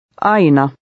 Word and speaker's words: Aina: The AI letter combination is pronounced I. Try to read number 1. – Aina.